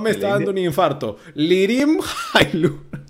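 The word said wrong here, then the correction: Lirim Hajrullahu: Lirin Jairulahu, perdón, es nacido en Kosovo, jugó Canadian Football.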